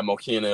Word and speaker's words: Mokena: Yeah, Mokena, Illinois.